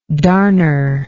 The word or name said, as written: darner